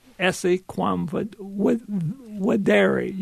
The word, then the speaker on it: Esse Quam Videre: Esse Quam Videre. You pronounce, I didn't know this at first, but V's in Latin are pronounced W's. Mm-hmm.